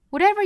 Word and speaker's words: whatever: Whatever you do in this life，首先呢 whatever 这里面 t 的发音变成了一个 flap t 闪音， whatever you do in this life，这个 do 和后面的 in 同样是连读的。